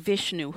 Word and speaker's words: Vishnu: I'm not sure if I'm pronouncing her name correctly. And Vishnu, who were they in your life? – You are pronouncing them correctly.